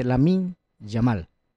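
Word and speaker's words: Lamine Yamal: Ustedes sabían que antes, imagínense, siendo él volante, hace un tiempo atrás, Lionel Messi le marcaba a Jamil Mahuad, del jugador del Barcelona. – Se escribe, se dice Lamin Yamal. – No es Jamil Mahuad.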